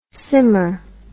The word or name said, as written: simmer dim